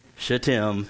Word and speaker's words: Shittim: So early in the morning, he set out from, and here's the correct pronunciation of the word, Shittim, yep.